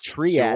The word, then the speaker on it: Triad: Am I pronouncing that right, Ken Flo? – Yes. – Triad. Sure. In our pre-show meeting, like triage.